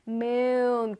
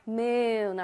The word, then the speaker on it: moon: 그냥 문 아니고요. 여는 문, 닫는 문 아니고 moon. 길게 발음을 해줘야 돼요. moon, okay?